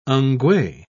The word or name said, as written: ngwee